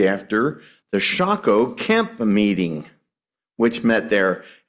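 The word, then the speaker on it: Socoh: I've been mispronouncing that. It's Shaco.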